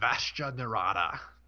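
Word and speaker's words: Vashta Nerada: Vashta Narada? Is that how you say it? – Vashta Narada. Yeah.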